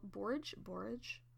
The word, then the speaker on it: borage: B-O-R-A-G-E. Okay, borage. – Borage. I think it's the pronunciation key says barrage. – Barrage? – Or borage. I don't know.